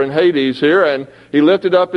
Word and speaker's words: Hades: it says that he lifted up his eyes in Hades. That's how they say it.